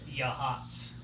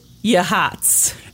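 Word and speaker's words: Yachats: Yahats. – What? Yeah. Bullshit, Yahats! – Okay, so in case the mic didn't pick that up, Norman just jumped in with the correct pronunciation. – It's Yahats.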